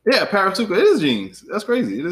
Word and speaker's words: Parasuco: That's how you say it, right? Parasuco. Yeah, P A R A S U C O.